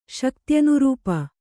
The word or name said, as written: ಶಕ್ತ್ಯನುರೂಪ